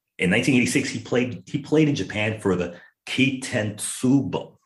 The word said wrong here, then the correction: Kintetsu Buffaloes: Kintetsu Buffaloes.